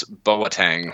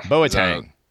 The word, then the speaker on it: Boateng: It's Kevin Prince Boateng. – Boateng. – Soccer player, played in Italy, Milan. – He got married in the metaverse. You can't make this stuff up, folks. Yesterday, the footballer Kevin Prince Boateng got married, and not in Milan, not in Madrid, but in the metaverse.